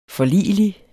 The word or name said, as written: forligelig